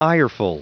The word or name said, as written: ireful